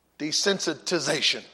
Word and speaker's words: desensitization: Rationalization leads you to desensitization. Desensitization. Excuse me. Forgot a syllable in there, desensitization.